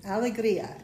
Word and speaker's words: Alegria: Is the artistic director of a new choral group called, let me say this right, Alegria? – Alegria. – Alegria. – It's Spanish for joy.